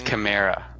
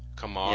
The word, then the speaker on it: Kamara: When are you gonna pronounce his name correctly? It's Kamara. – Kamara. Kamara.